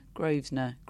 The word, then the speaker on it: Grosvenor: Grosvenor? Grosvenor. It's Grosvenor, isn't it? Mm.